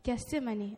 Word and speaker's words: Gethsemane: Gethsemane, okay.